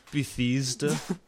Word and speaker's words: Bethesda: Did I pronounce that right? – Yes. – I'm just kidding. – It's Bethesda.